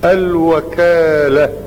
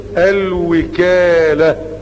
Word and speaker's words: الوكالة: أول فائدة الوكالة بفتح الواو، والفائدة الثانية الوكالة، فالكلمة تنطق بالفتح والكسر وكل منهما صحيح.